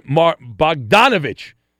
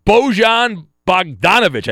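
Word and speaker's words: Bogdanović: And the great Bojan Bogdanovic, I think I butchered his name, Bogdanovic, he had 24 points. I don't know. Bojan Bogdanovic, I think.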